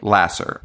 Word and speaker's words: Lasser: Like, so how do you say her name? – Louise Lasser? – Lasser.